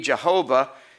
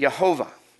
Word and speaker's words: Jehovah: The J in Jehovah is actually pronounced with the Y sound in Hebrew. How many of you already knew that? Raise your hand. It's the Y sound. So it would not be pronounced, to be pronounced correctly, it wouldn't be Jehovah. It would be Yehovah. Yehovah. The J was a Y sound.